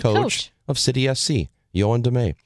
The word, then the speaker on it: Damet: He's talking about the new head coach of City SC, Yoan DeMay. And Harambe says, I believe it's pronounced damn it. It isn't. It's spelled D-A-M-E-T.